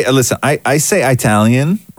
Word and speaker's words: Italian: I say Toronto, but you go Italian. – Yeah, I say Italian, right? Because it drives Italians crazy.